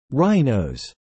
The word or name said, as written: rhinos